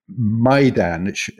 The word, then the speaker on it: Maidan: He goes on to say that the M-A-I in Maidan, i.e. the square in Kiev, where we were the other day, is pronounced just like that, my. Not, he says, like a distraught, alluring maiden waiting to be rescued by a knight of the round table.